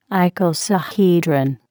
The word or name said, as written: icosahedron